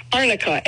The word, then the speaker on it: Arnica: How do you pronounce it, Arnica? – Arnica, A-R-N-I-C-A.